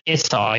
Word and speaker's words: isaw: Isaw? How do I say it? – Isaw, yeah.